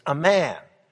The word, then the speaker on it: Amman: The capital city of the Ammonites was called Ramah, and Ramah today is called Amman, or as it's pronounced in the Middle East, Amman, the capital city of the Hashemite kingdom of Jordan.